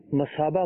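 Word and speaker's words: مثابة: یہ لفظ مشابہ نہیں ہے، مصابہ ہے. سے غلطی سے جو ہے وہ اس کا تلفظ شین پڑا گیا.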